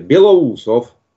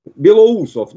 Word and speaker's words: Belousov: Ma voglio dire, Belousov mi sembra semplice anche per un italiano pronunciarlo, che poi tradotto alla lettera vuol dire baffo bianco. Belousov, non Belousov, perché guarda che non è solo un errore vostro, attenzione, lo sto vedendo, ascoltando e leggendo su tutti i media italiani.